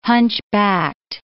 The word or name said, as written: hunchbacked